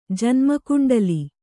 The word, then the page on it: ಜನ್ಮಕುಂಡಲಿ: janma kuṇḍali